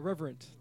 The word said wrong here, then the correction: Revenant: The Reverent, if I'm saying that right. – That's Revenant. Right.